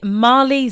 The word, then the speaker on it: Maleigh: Wow, Such a Time was the name of that track by Mali Zan. That's such a curious name because it's spelled M-A-L-E-I-G-H. Mali Zan, Z-A-N.